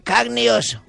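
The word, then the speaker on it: carne e osso: Se llama, se pronuncia carne y oso. Carne y oso. – ¿Oso? – Sí, que significa carne y hueso.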